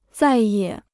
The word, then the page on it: 再也: zài yě